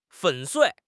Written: 粉碎

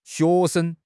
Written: xue2 sheng5

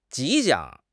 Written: 吉祥

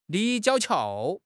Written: 立交桥

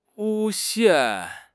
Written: 诬陷

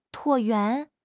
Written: 椭圆

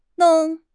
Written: long4

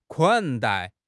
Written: 款待

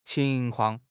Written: qing2 kuang4

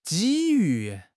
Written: 机遇

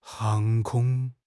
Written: 航空

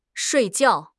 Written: shui4 jiao4

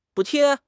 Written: 补贴

